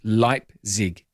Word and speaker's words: Leipzig: It's not Leipzig, it's Leipzig. Thank you.